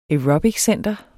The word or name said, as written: Aerobiccenter